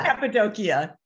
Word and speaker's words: Cappadocia: I think I really bastardized that, the name of that country, the Sufis in Cappadocia. How do you say that? – Cappadocia.